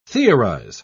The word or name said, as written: theorize